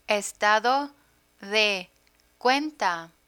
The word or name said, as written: estado de cuenta